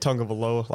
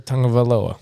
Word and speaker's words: Tagovailoa: Yeah, Tua Tagovailoa.